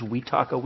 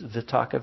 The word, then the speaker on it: vitaka: They pronounce the V's as W's, vitaka vichara. I pronounce them as V's, vitaka vichara, just like vipassana.